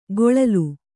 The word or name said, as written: ಕೊಳಲು